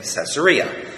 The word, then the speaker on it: Caesarea: And both are correctly spelled C-A-E-S-A-R-E-A. Now, I've never liked the word Caesar or Caesarea because it seems to me that it should be spelled like any normal word that has a long E sound, C-E-A-S-A-R. But no, some clever ancient fellow decided it should be C-A-E-S-A-R.